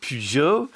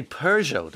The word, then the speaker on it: Peugeot: Some pronounce it Peugeot. Obviously, the Brits, they say Peugeot, don't they? Peugeot. We say Peugeot. That's what we say.